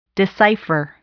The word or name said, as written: decipher